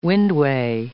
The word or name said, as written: windway